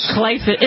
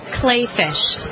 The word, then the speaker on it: Kleefisch: Clayfish. – It's... Say it again. – Clayfish. – Clayfish. Somebody tell me something else. – Well, it's spelled K-L-E-E-F-I-S-C-H. It's German.